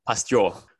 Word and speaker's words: Pasteur: Pasteur.